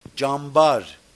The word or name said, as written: Tchambar